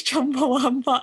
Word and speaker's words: Chumbawamba: Is that how you say it? I think it's Chumbawamba, I think.